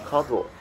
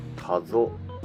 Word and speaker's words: 加須: 国道を歩いていると加須市の境に到達することができました。ここまで長かった羽生市とはお別れして加須市の方にここからは入っていきます。ちなみに加須ではなく加須が正しい発音らしいです。